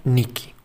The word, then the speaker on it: Nike: Su correcta pronunciación sería Niki.